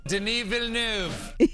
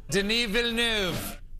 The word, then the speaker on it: Denis Villeneuve: It looks like it's spelled because it's D-E-N-I-S. So he said Dennis, and then it looks like Villanueva. But here, let's pronounce it again. – Denis Villeneuve.